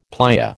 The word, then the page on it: playa: American English